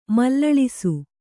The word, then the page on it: ಮಲ್ಲಳಿಸು: Kannada